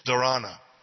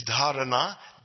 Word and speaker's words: dharana: Everyone mispronounces that. Everyone says dharana. Please don't say dharana. Okay. It's dharana, dhyana, and samadhi.